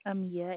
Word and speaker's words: Amia: Amia, A-M-I-A. – A-M-I-A.